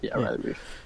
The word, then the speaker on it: Riley Reiff: It's Riley Reiff. Am I saying that right? – Yeah, Riley Reiff.